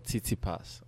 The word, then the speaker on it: Tsitsipas: Oh, Tsitsipas.